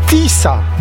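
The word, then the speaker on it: Tisza: Po węgiersku Tisa, nazwa pisana przez SZ, który wymawia się jako S.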